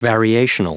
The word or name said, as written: variational